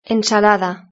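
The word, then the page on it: ensalada: Spanish (Spain)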